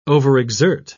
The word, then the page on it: overexert: British English